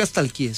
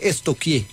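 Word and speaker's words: stalkeé: Porque no se dice nunca estalquié, se dice estoquié.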